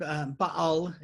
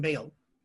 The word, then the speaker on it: Baal: The word Baal is actually how it's pronounced. I didn't know that until last year. I'd always called him Baal.